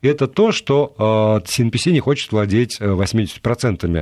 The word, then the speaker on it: CNPC: мгновенно практически приходит китайская государственная компания CNPC, если я не путаю там произношение. Нет, не путайте.